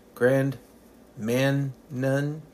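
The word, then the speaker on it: Grand Manan: It's spelled M-A-N-A-N, Grand Manum.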